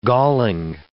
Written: galling

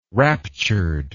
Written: raptured

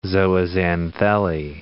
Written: zooxanthellae

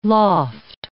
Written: loft

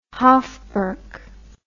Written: hofburg

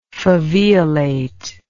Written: faveolate

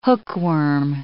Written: hookworm

